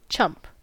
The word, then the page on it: chump: American English